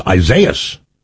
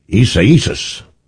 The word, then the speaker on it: Isaias: Yeah, when I first reading it, I thought it was Isaias. And they said, no, it's Isaias.